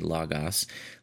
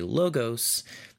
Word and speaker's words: Logos: But because people who aren't familiar with it might think it's L-A-G-A-S if we say Logos, we typically say Logos in other circumstances. So both are correct. Either one is fine.